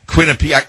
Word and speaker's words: Quinnipiac: I know that's not, you pronounce it Quinnipiac. I could never pronounce it.